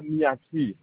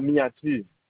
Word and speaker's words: Miadti: Le compteur, c'est à Miati. À Miati. M-I-A-D-T-I.